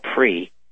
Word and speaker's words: PRI: The other is called PRI, which is the Spanish pronunciation.